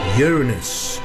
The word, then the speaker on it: Uranus: Uranus.